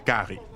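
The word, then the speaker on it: cari: In La Réunion, they visit Creole houses and eat rice, often with a local curry, pronounced gari.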